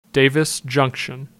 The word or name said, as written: Davis Junction